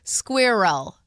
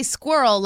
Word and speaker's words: squirrel: So it would be like squirrel. – How do you say it? – I say squirrel like it rhymes with girl.